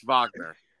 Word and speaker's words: Wagner: You have it right, but it's pronounced Wagner. – Mo Wagner went to Michigan. Correct. I don't know who Mo Wagner is. – That's how you say it, you fucking loser. Shut up. – I didn't know that. I actually didn't know that. – Yes, Mo Wagner. It's German.